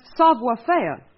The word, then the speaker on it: savoir faire: She said he had a save affair. – Oh, that's wrong, honey. The word is pronounced savoir faire.